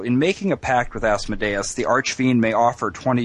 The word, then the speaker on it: Asmodeus: Was it Asmodeus? – Asmodeus. – Asmodeus. Oh, like Deus.